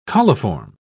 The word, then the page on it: coliform: British English